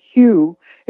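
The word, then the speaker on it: HU: One of my favorites is there's a sacred ancient love song to God called Hugh.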